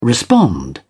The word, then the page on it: respond: British English